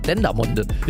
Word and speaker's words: Dendermonde: Ich dachte zuerst, weißt du, wenn das in Belgien ist, könnte ja auch Französisch sein und das dann Dendermonde oder so ausgesprochen wird. Oder ja. Dendermonde oder irgendwie sowas. Aber es ist wirklich einfach Dendermonde.